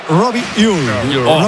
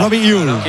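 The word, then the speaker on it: Robbie Ure: A ver, el club pasó incluso un audio, lo ha pasado. Y en concreto sería Robi Ur. Robi Ur.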